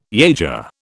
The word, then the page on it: yager: British English